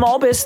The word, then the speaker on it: Albus: I'm Sam Albus, the co-host and co-writer of this show.